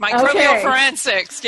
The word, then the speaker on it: microbial forensics: So I'm going to make you laugh, but did I say that correctly? Microbial forensics? – Yes, perfect. Microbial forensics.